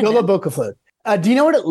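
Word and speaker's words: jólabókaflóð: So it's Jola Boca Flod. Is that how you pronounce it? Or is it Jola Boca Flod? – Well, both. Well done. Well done.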